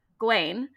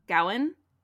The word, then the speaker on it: Gawain: Now, I'm going to say Gawain, probably, instead of, like, a lot of people say Gawain. – Mm-hmm. – I think at the time that this was written, it would have been pronounced Gawain.